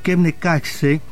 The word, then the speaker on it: Kebnekaise: Kebnekaise,